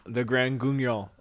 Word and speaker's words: Grand Guignol: And I'm pronouncing that correctly. That is French. I'm probably not pronouncing it 100% correctly because I'm not using like the French accent to it, bonjour. But it's Guignol. But it's that's how it's pronounced, the Grand Guignol.